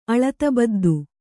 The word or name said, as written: ಅಳತಬದ್ದು